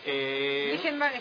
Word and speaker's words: Blijenbergh: Un libro de la editorial Caja Negra que se llama Las Cartas del Mal, que es la correspondencia entre Baruch de Espinosa y Blinkenberg, si es que así se pronuncia. – A ver, Blinkenberg está bien, correspondencia Espinosa-Blinkenberg.